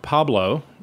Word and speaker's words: Pableaux: I spell my name, Pablo, spelled in the South Louisiana fashion, or the imaginary South Louisiana fashion. It is P-A-B-L-E-A-U-X.